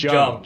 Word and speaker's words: Jung: Jung. – Junk? – Spell that. Jung. J-U-N-G.